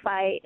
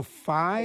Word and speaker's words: Fi: Google Fi, is it pronounced? How's it spelled, Fi? – Yep, Google dot F-I. – Oh, dot S-I, Google Sci. Is that right? – No, F like Frank.